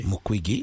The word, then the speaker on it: Mukwege: Pour rencontrer d'abord beaucoup d'artistes, mais aussi voir ou interviewer monsieur le docteur Mukwege.